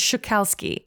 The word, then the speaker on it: Szukalski: Yeah, so his name is Joukowsky, and that's spelled S-Z-U-K-A-L-S-K-I.